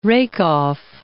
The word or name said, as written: rake-off